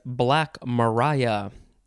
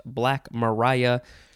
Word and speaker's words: Black Maria: The first word is Black Mariah. It looks like it's Black Maria, but it is pronounced Black Mariah.